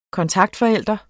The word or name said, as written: kontaktforælder